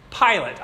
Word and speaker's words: Pilate: His name is Pilate. I always thought he flew an airplane when I was a kid, but that's not how it's spelled. P-I-L-A-T-E.